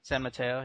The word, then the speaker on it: San Mateo: San Mateo, yeah.